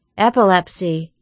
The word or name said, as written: epilepsy